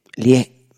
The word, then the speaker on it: Lié: Lie, por acento en la E.